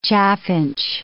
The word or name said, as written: chaffinch